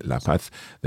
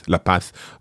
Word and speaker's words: La Paz: Of course, so the path, La Paz, we're saying La Paz because we are speaking with a Spain-Spanish pronunciation. Of course, if you were in Bolivia, you'd be much more likely to hear La Paz, and therefore the adjective that is linked to La Paz or La Paz is paceño or paceño.